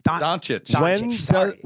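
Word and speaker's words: Dončić: After he stopped talking about Luka Doncic. – Say his name right, bro. – What is it? – Doncic.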